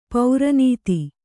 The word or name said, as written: ಪೌರನೀತಿ